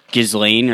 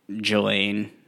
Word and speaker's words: Ghislaine: I just said it how it was spelled. So it was like Ghislaine or Ghislaine, Ghislaine, Jizz. – Jizz face. – Yeah. But then I heard someone say Jelaine. So I think the S is just silent, which I don't know why we have silent letters.